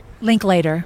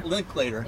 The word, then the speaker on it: Linklater: Anais, I get wrong, and Rick Linkletter, right? – Linklater. – Linklater. Yeah.